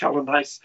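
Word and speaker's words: Geldenhuys: But yeah, that forward pack from Bay of Plenty, where you've got Aidan Ross, Nathan Harris, Ross, Glenn Hines, that front row really does pack a punch. – Yeah, no, you're right. Just on that pronounce it. That's Ross Hellenhuis, well-travelled guy. – Hellenhuis, sorry.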